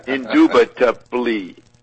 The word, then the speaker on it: indubitably: He can't spell it the way he said it. I-N-D-U-B indubitably. I-N-D-U-B-I-T-A-B-L-Y.